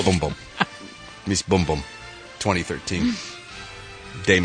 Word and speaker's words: Bumbum: Apparently, it's Miss Boom Boom. – Boom Boom. – Boom Boom. – Boom. – I was saying Miss Bum Bum. – Right. – Because it's spelled B-U-M-B-U-M.